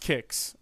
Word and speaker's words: Qix: Oh, and it is pronounced Kicks. Okay. – Oh, is it? Is that how you say it? So, yeah, and the only reason we know that in America is because we had a cereal called Kicks as well. And that's how they pronounce it.